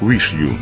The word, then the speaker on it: WIXIW: Некоторые источники считают, что это странное название следует произносить как Wish You.